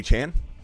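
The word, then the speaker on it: Achane: Who's A-Chain? – Number one defense. – Who's A-Chain? – A-Chain. – You mean A-Chan? – Yeah, exactly. A-Chan.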